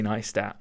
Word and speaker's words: Neistat: Lo-fi and Casey Neistat. Neistat is spelt N-E-I-S-T-A-T.